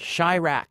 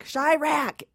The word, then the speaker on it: Chiraq: Chiraq. – Chiraq. It's like Chicago and do rock. – That's right. – All in one word.